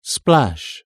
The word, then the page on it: splash: British English